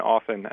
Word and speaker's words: often: Because to me, it's always been often, and the audible T version just kind of grates on my ears a little bit.